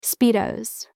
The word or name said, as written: speedos